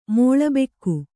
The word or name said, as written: ಮಾಳಬೆಕ್ಕು